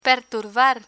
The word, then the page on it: perturbar: Spanish